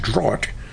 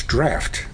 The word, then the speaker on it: draught: Now that word looks like it would be pronounced draught, but they tell me it's draught.